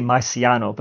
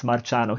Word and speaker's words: Marciano: Americans say Marciano, but we pronounce Marciano.